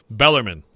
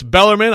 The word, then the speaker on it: Bellarmine: But then he quickly corrected himself. Bellarmine. So there you go. It's Bellarmine.